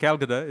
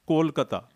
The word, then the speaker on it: Kolkata: It's not Calcutta. It's Kolkata. – Yes. You know, Bengali pronunciation.